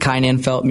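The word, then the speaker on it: Kai Nanfelt: So I'm at Kai Nanfeld Music on Instagram, at Kai Nanfeld on TikTok. – And spell that for us if you don't mind. – Yeah. K-A-I is my first name.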